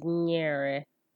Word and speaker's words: nyere: Next, there is NY, which is pronounced nyi. An example of a word with the nyi sound is nyere, which means to give.